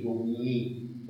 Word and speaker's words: Domini: Pero se tiene que considerar la pronunciación francesa, Pax in nomine Domini, porque este I da luego una rima.